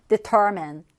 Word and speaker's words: determine: Determine, determine. 이 단어를 읽을 때 많은 친구들이 determine이라고 읽더라고요. determine이 아니라 determine이라고 해서 중간에 강세가 오게 읽으시면 자연스럽게 끝에 m-i-n-e를 mean이라고 읽습니다.